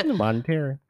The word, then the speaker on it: monetary: I'm referring to monetary means. I hope that I said that right. I don't know how to say that word. – Monetary. – I believe it's monetary.